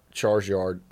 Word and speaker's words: Charizard: Charizard. Charizard. You trade him a Charizard for Rhymes with Marsyard.